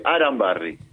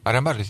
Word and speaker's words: Arambarri: Porque me corrigió el conductor, se dice Arambarri. – Arambarri, sí.